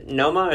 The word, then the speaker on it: Noma: Noma, or is it Numa? I don't know if I'm saying it right. – Noma.